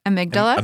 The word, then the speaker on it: amygdala: So with that kind of like working from the how do you say it again? – Amygdala. – Amygdala. I wanted to say amygdala, and that's not right.